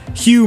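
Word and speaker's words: Huw: That's H U W. How would you pronounce that word? Hugh Mark Bennett.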